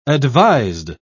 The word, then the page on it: advised: British English